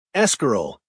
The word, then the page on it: escarole: American English